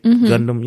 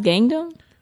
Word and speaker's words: Gundam: You know Gundam, the big Gundam, you know, Voltron-looking things, you know what I'm saying? That's coming out. – Sure it's not pronounced Gangnam? – No, it's Gundam.